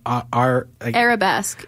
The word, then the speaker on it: arabesque: It's called Arabesque.